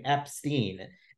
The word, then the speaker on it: Epstein: But just to be put it very plain and simple, the family, the Epstein family, pronounced their last name Epstein. And that comes from Brian's mom. So I believe if Brian's mother says the correct way to pronounce our family's name is Epstein, then that's probably the correct way to say the name.